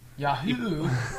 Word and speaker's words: Yahoo: Yahoo's so weird. – Yahoo. – You put emphasis on the first syllable.